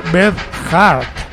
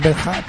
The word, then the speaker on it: Beth Hart: Bueno, se escribe B-E-T-H, es decir, Beth, y luego H-A-R-T, o sea, Beth Hart. Bueno, yo diría Beth Hart, pero bueno, ellos dicen Beth Hart, pues todo es parecido, pero bueno, si no, buscáis Joe Bonamassa y su cantante, o ponéis así y os saldrá.